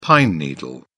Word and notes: pine needle: British English

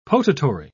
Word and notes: potatory: British English

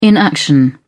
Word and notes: inaction: British English

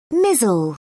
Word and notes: mizzle: British English

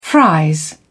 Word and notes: fries: British English

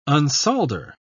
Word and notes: unsolder: British English

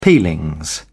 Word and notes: peelings: British English

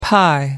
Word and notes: pie: American English

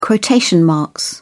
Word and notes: quotation marks: British English

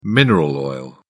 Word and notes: mineral oil: British English

